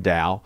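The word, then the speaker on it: DAOU: It's spelled D-A-O-U-U-O, but it's pronounced Dow.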